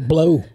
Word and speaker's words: Bleu: It's BLEU Sushi. – Ooh, I can pronounce. Ooh, I can still pronounce it blue, yeah. – Though. – Yeah. – Either way you spell it, I'll get that one right. – I was waiting for him to say blue. – No, it's blue.